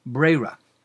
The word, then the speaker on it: Breira: And that's the question of Yesh Breira or Ein Breira. In traditional circles, this is pronounced Breira.